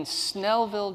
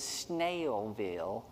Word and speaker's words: Snellville: I grew up in Snellville, Georgia. And dealing with the annoying habit of outsiders who like to deliberately mispronounce it as Snailville was exhausting enough.